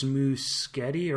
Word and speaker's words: Muschietti: Like, yeah, I think it's Muschietti, or he called it Muschietti. It's definitely not Muschietti. He definitely didn't say that. – It rhymes with spaghetti, not machete. Wait a minute, those words rhyme with each other.